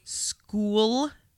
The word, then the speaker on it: Schuylkill: Schuylkill? – Yeah. – As in pee pee. – Well, no, it's not spelled that way, but it is pronounced that way.